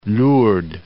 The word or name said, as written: Lourdes